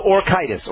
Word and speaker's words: orchitis: It's O-R-C-H. – Oh, orchitis.